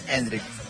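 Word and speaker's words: Endrick: Eso descarta la entrada de Endriki. – Puede ser. – Yo quería ver a Endriki. – No me estás cerrando del todo la pronunciación. – Endrik, ok.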